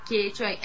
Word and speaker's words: Quechua: Quechua? – Tá, Quechua.